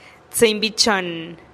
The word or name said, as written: tsïinn bi chonn